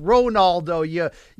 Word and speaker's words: Ronaldo: his name is 100% pronounced Ronaldo. You pronunciation yes.